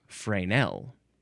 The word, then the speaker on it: Fresnel: It's interesting that Fresnel is the first one, because I guess most Americans, they're like, they see that S and they want to pronounce the S, but I think the more proper pronunciation would be Fresnel.